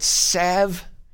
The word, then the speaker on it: salve: Oh, and by the way, these people who call it salve, it's from a Latin word, salvere, salvere. There's an L in there, salve. It's related to the word salvo, which is pronounced salvo.